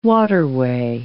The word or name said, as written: waterway